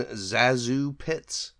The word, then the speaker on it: ZaSu Pitts: But for this episode, we are going to look at the life and career of one ZaSu Pitts, I should say. That's the correct pronunciation.